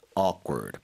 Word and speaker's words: awkward: So things felt a bit awkward. 발음 다시 한 번요. Awkward. – Awkward. 약간의 okay. 그 크 사운드보다는 끄에 그, 가까워요.